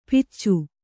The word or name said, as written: ಪಿಚ್ಚು